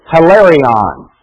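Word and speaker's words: hilarion (ἱλαρόν): if I pronounce it in the Greek, it sounds like this, hilarion.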